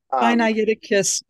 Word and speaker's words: Sinaiticus: specifically Codex Synacticus and Codex Vaticanus. I think I'm saying that correctly. – Sinaiticus and Vaticanus. – There you go.